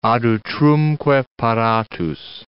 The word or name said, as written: ad utrumque paratus